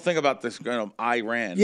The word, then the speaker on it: Iran: Am I saying it right? I'm not one insulting nobody. Iran? – Iran, that's correct.